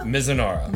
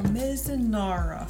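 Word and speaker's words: Mizunara: And this is the, I always say this wrong. Is it Mizanara? – Mizanara. – Mizanara finish.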